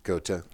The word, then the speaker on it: Goethe: Fichte, Goethe, how do you spell, how do you pronounce that again? – Goethe.